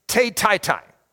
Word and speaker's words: tetity: If you're getting this via podcast, you're getting T-E-T-I-T-Y, okay? So it's te-tai-tai is how you say it, okay?